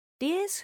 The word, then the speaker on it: DS: Dies, whose pronunciation is a word play with the word goddess in French, was very different from all the cars of its time.